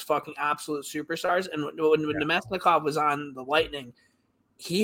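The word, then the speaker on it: Namestnikov: Nemesnikov.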